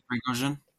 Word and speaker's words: Prigozhin: Evgeny Prigozhin. – Yeah, I can never say his name correctly. Prigozhin.